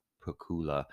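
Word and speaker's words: Pacuła: I think her last name is pronounced Pukula, the Polish actor who's in a wonderful camp fest called The Kiss.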